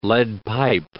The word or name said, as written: lead-pipe